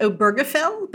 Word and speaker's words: Obergefell: And I'll tell you as an aside, I don't know if, is it Obergefell or Obergefell? I've heard it several different ways. And at some point I'm just going to say Bob, because I have no idea how to pronounce it. – Yeah. So I think that's a valid question. I pronounce it Obergefell, but I've also heard it said many different ways. So I'm just going to continue to say Obergefell.